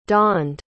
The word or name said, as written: donned